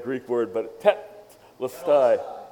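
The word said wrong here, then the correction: Telestai (τετέλεσται): Telestai, there we go.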